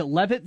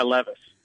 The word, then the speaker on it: Levis: If they wouldn't get, is it Levitt? Is that how you say it, the kid from Connecticut? – Yeah, Levis.